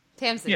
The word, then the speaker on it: Tamsin: Tamsin.